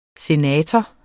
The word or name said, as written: Senatoren